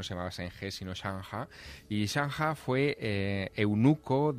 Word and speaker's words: Zheng He: Allí no se llama Zheng He, allí se llama Chang He, que es...